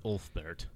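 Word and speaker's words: Ulfberht: It's U-L-F-B-E-R-H-T. – Huh, okay. – Ulfbert. – Yeah. And I watched a Ulfbert video on this, so I know it's Ulfbert. – Wow. – Because I didn't know how to pronounce it.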